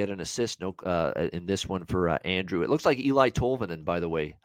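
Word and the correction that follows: Eeli Tolvanen: Ellie Tolvanen is actually how you say it.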